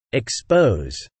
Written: expose

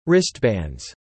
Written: wristbands

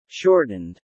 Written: shortened

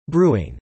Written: brewing

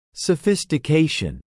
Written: sophistication

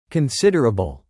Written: considerable

